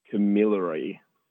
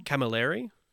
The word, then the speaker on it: Camilleri: But in terms of sort of, there's basically like three major sort of grower cooperatives there, and that is Delicata, Marsovin, and Camarelli. Oh, sorry, Camilleri. Definitely pronounced. – Do you mean wrong. – Camilleri? – Camilleri, maybe. Yes.